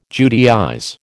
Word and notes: Judaize: American English

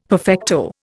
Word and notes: perfecto: Indian

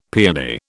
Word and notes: paeony: British English

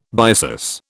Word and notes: byssus: British English

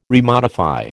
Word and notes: remodify: American English

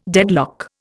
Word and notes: deadlock: Indian

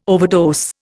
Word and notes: overdose: Indian

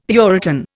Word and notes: puritan: Indian

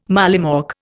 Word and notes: mollymawk: Indian

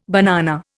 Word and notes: banana: Indian

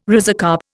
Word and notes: rhizocarp: Indian

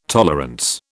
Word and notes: tolerance: British English

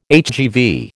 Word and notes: HGV: American English

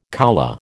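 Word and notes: coulée: American English

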